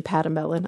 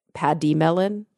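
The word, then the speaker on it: pademelon: Actually, that's the American pronunciation, patamelon. I looked up how to say it out loud. The British and or Australian pronunciation, I think, is more like paddy melon, like the E, paddy melon.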